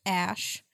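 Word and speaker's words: Æ: So it's the letter X, Then the AE is pronounced Ash.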